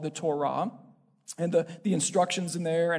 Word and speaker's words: Torah: Torah, sometimes it gets pronounced Torah, and it can be translated as law or instructions.